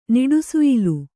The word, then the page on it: ನಿಡುಸುಯಿಲು: niḍusuyilu